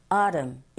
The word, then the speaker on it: autumn: autumn 같은 경우에는 autumn 하셔도 되고 영국식 발음이겠죠. 북미권에서는 그래도 t 사운드를 조금 네 약하게 발음하는 경우가 많은지라 autumn 이렇게 들리게 되고 발음하시면 됩니다. autumn was short 그리고 this year 하시면 되겠죠.